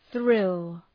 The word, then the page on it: thrill: ɵrıl